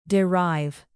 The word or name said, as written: derive